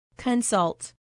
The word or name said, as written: consult